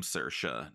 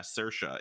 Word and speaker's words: Saoirse: Wild that all of us just know how to pronounce the name Saoirse now, because mm-hmm.